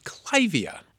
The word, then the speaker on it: Clivia: It's actually pronounced Clivia.